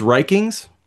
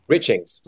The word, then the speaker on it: Richings: But you also have an interaction with Mr. Stackhouse, played by Julian, is it pronounced Rikings? – Richings, Richings.